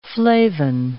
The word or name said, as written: flavin